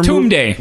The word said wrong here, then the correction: Tome: Isn't it pronounced Tome? – It is.